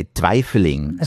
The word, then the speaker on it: twijfeling: Yeah, in Dutch we would say twijfeling.